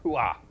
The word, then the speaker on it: Puah: Yes, I listened to the pronunciation. That is how you say it. Puah.